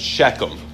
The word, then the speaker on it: Shechem: Yeah, it's pronounced Shechem.